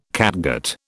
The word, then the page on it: catgut: British English